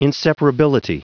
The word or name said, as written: inseparability